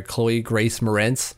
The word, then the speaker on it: Chloe Grace Moretz: My next one comes from The Tonight Show, who had, I'm going to butcher her name, I'm sorry, Chloe Grace Moretz.